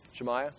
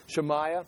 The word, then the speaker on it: Shemaiah: Give me a pronunciation. Shemaiah? Okay, excellent. Okay. Shemaiah.